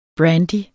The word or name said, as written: brandy